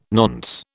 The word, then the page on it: nonce: British English